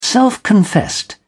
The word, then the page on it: self-confessed: British English